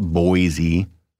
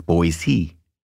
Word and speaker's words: Boise: And let's talk about the pronunciation of Boise, because it's not Boise, like noisy. It's Boise. Boise. And they even have that on their website and on t-shirts and things like that.